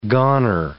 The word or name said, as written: goner